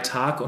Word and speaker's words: Tag: And that Tag, this is one, so one consonant following, and it's a long vowel.